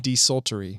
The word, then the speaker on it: desultory: You say desultory or just sultry? – Desultory. – Desultory. – Yeah.